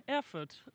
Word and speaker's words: Erfurt: I staden Erfurt. – Mm, bra uttal. – Tack. Eller det var alltså inte bra uttal? – nej, Erfurt.